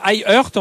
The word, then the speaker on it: iHeart: Alors i avec un i, voilà, on n'est pas, c'est pas le e de l'anglais. iHeart, on va dire si on le prononce en anglais.